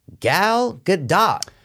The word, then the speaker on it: Gal Gadot: Don't know if I'm pronouncing it right, but my number one worst actor is Gal Gadot. – You are saying it pretty close, dude. – Okay. – Yeah, you could say either Gal Gadot.